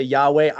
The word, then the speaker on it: Yahweh: The kind of the standard scholarly consensus, people say Yahweh. I am one of the people though who pronounces it Yehovah.